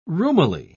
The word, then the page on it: roomily: British English